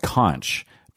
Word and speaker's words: conch: And it's spelled conch, but you pronounce it conch.